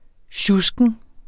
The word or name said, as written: sjusken